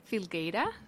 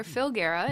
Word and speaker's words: Filgueira: You can say it Filgueira in Spanish or Filgueira in English.